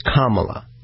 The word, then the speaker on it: Kamala: call her Mamala because it rhymes with Kamala.